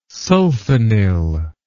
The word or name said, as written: sulfonyl